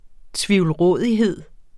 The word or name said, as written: tvivlrådighed